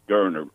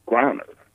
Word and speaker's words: Griner: So, I mean, I've always heard this Gurner, like, you know, kind of Gurner. – Yeah, yeah. – But he pronounced it the other day and it's Griner, you know.